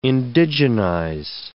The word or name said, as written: indigenize